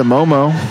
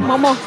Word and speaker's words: Mawmaw: Momo. – Maybe yeah. He came up from Louisiana. – But it's spelled M-A-W-M-A-W, but. – Oh, Momo spelled M-O-M-O.